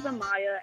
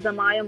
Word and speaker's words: Amaya: Hi, my name is Amaya, a.k.a. Jam. My YouTube name is Amaya Morris. It's spelled A-M-A-Y-A, last name Morris, M-O-R-R-I-S, a.k.a.